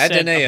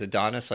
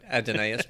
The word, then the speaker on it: Adonais: Yes, that's is that, is that how you pronounce it? Okay. – Recently, yeah. I used to think it was Adonis like everybody else yeah. until you started really getting deepened with the Star Trek folks, but Adonais makes sense now, yes.